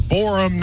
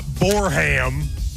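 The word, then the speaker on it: Boreham: It's Borum, not Borham.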